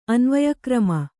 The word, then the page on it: ಅನ್ವಯಕ್ರಮ: anvayakrama